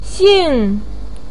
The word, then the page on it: xing4: pinyin